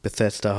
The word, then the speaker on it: Bethesda: Bethesda hat die Reihe gemacht äh, Doom, äh, die Reihe Fallout und dann die.